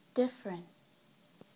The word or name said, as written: different